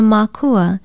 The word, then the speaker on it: makua: Since the main stalk of a plant is thought of as the parent, you can also call it a makua. It is spelled M-A-K-U-A.